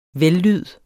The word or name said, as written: vellyd